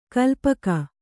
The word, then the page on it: ಕಾಲ್ಪಕ: kālpaka